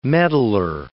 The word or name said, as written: meddler